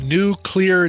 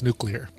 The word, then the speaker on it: nuclear: Came from an album called New Clear Days. And that was a good way for me to, as a kid, remember how to pronounce nuclear.